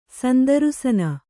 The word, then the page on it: ಸಂದರುಸನ: sandarusana